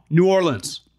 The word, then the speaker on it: New Orleans: I used to say it New Orleans, and a bunch of people DM me within the last maybe 2 years, like, you're saying it wrong, New Orleans.